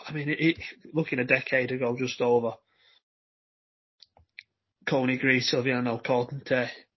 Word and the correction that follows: Silviniaco Conte: Sorry, Silviniaco Conte.